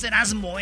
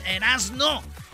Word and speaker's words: Erazno: Bueno, Erasno es E-R-A-Z-N-O. No es, no es Erasmo, eh, es Erasno. Y la Chocolata. Chocolata se escribe con K en la segunda C. Es C-H-O-K-O-L-A.